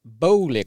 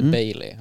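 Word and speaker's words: Boehly: Ansiktet utåt för de nya ägarna kommer bli Todd Bowley, kommer fram till att man säger. Inte Bailey. Ja, Todd Bowley.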